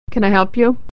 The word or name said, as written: can